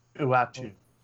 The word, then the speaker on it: Uatu: Utah Oh, U- Uata. – Uatu.